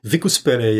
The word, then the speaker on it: Vicus Pelle: It's spelled Vicuspelle, right? – Exactly. – One C, right? – One C, two L.